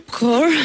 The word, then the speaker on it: Kaur: Kaur. – Yeah. You know everything of it. Is it K? Because it's K-A-U-R. – Kaur. It's like driving your core.